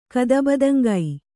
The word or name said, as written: ಕದಬದಂಗೈ